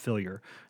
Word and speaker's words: Fillier: It is Wap Fillier. So that is, as in like, fill your gas tank, Wap Fillier. That's how you pronounce his name.